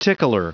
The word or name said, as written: tickler